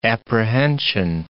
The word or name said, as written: apprehension